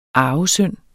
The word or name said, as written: arvesynd